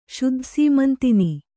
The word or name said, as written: ಶುದ್ಧಸೀಮಂತಿನಿ